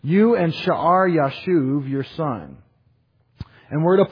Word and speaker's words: Yashub: Why'd I spell it Yashub, Y-A-S-H-U-B, and I pronounce it Yashuv?